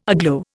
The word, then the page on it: aglow: Indian